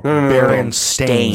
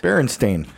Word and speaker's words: Berenstain: But how do you say it? – Berenstain. – I'm out. – You're from the old timeline. – I'm not doing it. – The original timeline. – No one pronounced it that way.